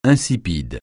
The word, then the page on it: insipide: francais